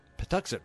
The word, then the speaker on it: Patuxent: Okay, my new station is, tough to pronounce word, Patowix River? Patuxent River is pretty sweet and things are going pretty well, though my interest in WoW has wavered as of late.